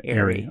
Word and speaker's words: Aari: Ari McDonald, of course, A-A-R-I. – Yes. – So, and the WNBA draft her senior year, she had a sign behind her that said, like, spelled out phonetically, Ari.